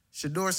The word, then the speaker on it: Shedeur: Shedeur Sanders.